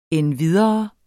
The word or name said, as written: endvidere